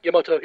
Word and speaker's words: Yamato: First one is Star Blazers, aka Space Battleship Yamato? Am I saying it wrong? – Yamato, yeah.